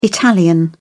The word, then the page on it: italian: British English